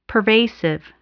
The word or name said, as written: pervasive